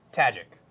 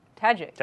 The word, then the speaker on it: Tajik: It's not Tajik. – It's no. – Tajik. – Tajik. – Just right. Say it like how you read it.